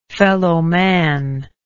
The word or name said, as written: fellowman